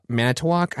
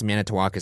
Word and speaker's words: Manitowoc: Basically, Stephen Avery, a resident of Manitowoc, I think it's Manitowoc is how you pronounce it, Wisconsin.